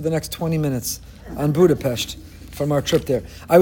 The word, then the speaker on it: Budapest: This past Friday afternoon, I was sitting in a restaurant in Budapest. That's the incorrect way to pronounce it. Now as an expert, I'll tell you, it's Budapest.